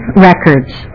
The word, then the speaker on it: Records: I'm Nan Records. And it's spelled just like it sounds.